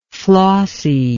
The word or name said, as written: flossie